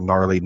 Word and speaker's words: Gnarly: Just look for Gnarly 90s, spelled with a G. As Jeff and I have joked since we come up with that, it's a G thing. And what up, G? – Yep. You found the G spot. There's a lot of stuff to do with the G. So, the G is silent, but we're not.